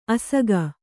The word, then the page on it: ಅಸಗ: kannada